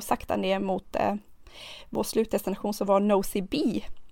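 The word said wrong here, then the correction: Nosy Be: eller Nosy Bay uttalar man det, Just det.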